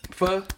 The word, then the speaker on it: pho: Pho? – It's got my heart, yeah. Some people might pronounce it as pho, but it's pronounced pho.